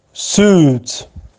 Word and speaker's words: suit: suit suit suit آیش تلفظ نمیشه suit suit convenient convenient convenient convenience also eligible eligible eligible.